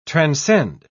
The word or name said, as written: transcend